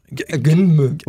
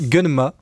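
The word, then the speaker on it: Gunnm: Gunmu. – Alors ça se prononce Gunma.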